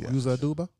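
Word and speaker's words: Uzo Aduba: But Yuza Aduba. Crazy Eyes. – Uh-huh. I don't know. – Oh, yeah. Crazy. – Yuza Aduba? – Yeah. – So I did say it right.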